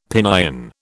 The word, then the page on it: Pinyin: British English